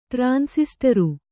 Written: ಟ್ರಾನ್ಸಿಸ್ಟರು

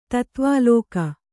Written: ತತ್ವಾಲೋಕ

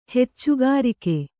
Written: ಹೆಚ್ಚುಗಾರಿಕೆ

